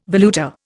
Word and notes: valuta: Indian

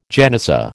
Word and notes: genizah: American English

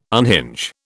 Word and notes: unhinge: British English

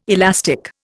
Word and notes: elastic: Indian

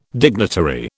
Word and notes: dignitary: British English

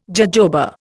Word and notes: jojoba: Indian

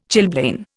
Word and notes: chilblain: Indian

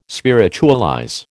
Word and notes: spiritualize: American English

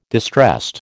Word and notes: distressed: American English

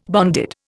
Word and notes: bonded: Indian

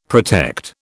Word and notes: protect: British English

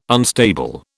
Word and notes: unstable: British English

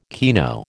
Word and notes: kino: American English

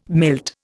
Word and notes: milt: Indian

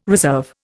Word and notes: reserve: Indian

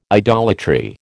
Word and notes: idolatry: American English

